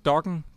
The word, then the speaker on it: Dokken: Jamen, do, øh, dokken, som den nok skal udtales, det er jeg ret sikker på.